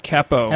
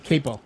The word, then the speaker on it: Capo: And that's brand new, Capo. – Heaven knows, Capo. – I'm sorry. My mistake. – No. Well, I always pronounce it Capo. What do I know? But C-A-P-O.